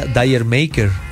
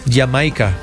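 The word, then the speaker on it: D'yer Mak'er: no se pronuncia Dyer Maker, como todo el mundo cree, sino Jamaica,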